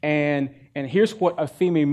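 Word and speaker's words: aphemi (ἀφίημι): So the first one of these words is aphemi. Aphemi. You can see the transliteration there in the parenthesis there. That's how you would pronounce it.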